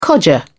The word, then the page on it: codger: British English